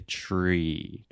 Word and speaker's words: tree: Alexia initially said three, three house, which is like a Portuguese pronunciation. You want to say tree, tree.